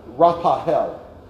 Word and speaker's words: Raphael: You see, this is showing us right here that this is the healing angel, Raphael. I guess I better pronounce it right. Raphael.